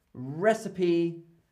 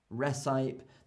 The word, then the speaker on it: recipe: The E in recipe is pronounced, isn't it? Yes, so that is a lovely exception. I'm going to write that down. I'm going to make that a learning item. So exception, that's a really nice one. recipe. So we say recipe and not recipe.